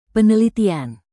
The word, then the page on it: penelitian: indonesian